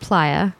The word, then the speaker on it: playa: So number one, it's pronounced playa. – Okay. – Because it's like a dried up lake bed, so they call it the playa.